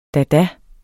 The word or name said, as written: dada